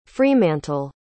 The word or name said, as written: fremantle